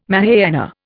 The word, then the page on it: Mahayana: Indian